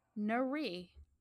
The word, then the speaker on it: Nurie: And so when she finally got pregnant again, they named their first child Nurie, not Marie, but Nurie.